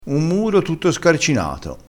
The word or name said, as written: scarcinato